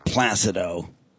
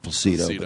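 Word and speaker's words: Plácido: That's how everyone dealt with Placido. It's actually Placido, but she mispronounces it too.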